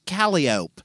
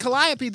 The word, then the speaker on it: Calliope: Also, my favorite is Calliope Street is pronounced Calliope. And if you pronounce it Calliope, they will not know what you're talking about.